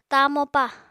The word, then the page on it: tamopa: apatani